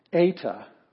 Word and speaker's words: Eta: Eta, it's the E sound is in they, A.